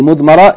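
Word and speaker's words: Mudmara (مضمرة): Yani, mudmara is spelt meem, bad, meem, ra, and tarmabuta.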